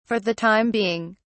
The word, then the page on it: for the time being: British English